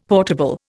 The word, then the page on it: portable: Indian